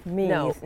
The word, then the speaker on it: Meath: Not Meath, Meath. – No. But everyone says Meath is maybe a better pronunciation of it, but Meath, it's a bit softer maybe in our accent.